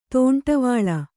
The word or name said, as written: ದಂಡವಳ